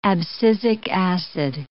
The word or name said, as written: abscisic acid